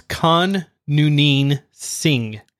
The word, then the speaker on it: Khan Noonien Singh: So I'm probably going to butcher this, but, you know, it's Khan Noonien Singh.